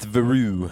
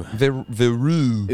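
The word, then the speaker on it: Verreaux: Roish speculated that they could have been the Varroes Eagles? – Right? Yeah, it's Veru. – Veru.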